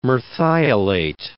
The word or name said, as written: Merthiolate